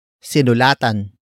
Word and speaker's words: sinulatan: Word for word it's, sinulatan ko si Mayor.